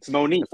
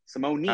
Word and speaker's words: Simone: Simone. I'm looking at it right now. Simone. Not nay. Knee. Simone. – All right. Knee. Marco Simone. Thank you. Of the four things that matter at Marco Simone, he ranked outside the top 125.